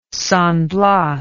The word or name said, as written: San Blas